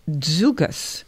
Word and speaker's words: Dziugas: It's called Dzugas.